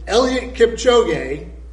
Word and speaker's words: Eliud Kipchoge: And Eliud Kipchoge,